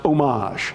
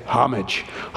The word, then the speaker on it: homage: All right, I know some of you out there are going, that's a French word. It's pronounced homage. I'm not French. I'm Appalachian. I say homage. Homage.